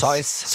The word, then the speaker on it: Seuss: Es ist ein Animationsfilm aus Amerika vom Dr. Spricht man ihn aus Süß oder Seuss? – Seuss.